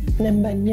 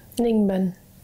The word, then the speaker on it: Ninh Binh: Ninh, Binh, yeah. – Is that how you pronounce it? – Ninh, Binh.